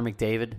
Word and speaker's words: McDavid: Another thing around the NHL, your boy Connor McDavid gets 100 points. What? – Try that again. – Your boy Connor McDavid.